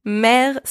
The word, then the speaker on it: mer: Mère spelled M-E-R means seam. So in these three words, the letter M is pronounced M, the letters A and I together, and also the letter E with a grave accent, and the letter E from the third word are all pronounced E.